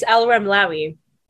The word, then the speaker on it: Alramlawi: It's Alramlawi.